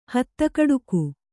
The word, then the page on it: ಹತ್ತಕಡಕು: hatta kaḍaku